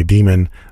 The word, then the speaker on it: Demon: When we say demon, that's from De Montfort University. Dem on FM is how we like to pronounce it.